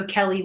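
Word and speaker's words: Kelley: My name is spelled K-E-L-L-E-Y. I'm also Kelly Weaver on LinkedIn.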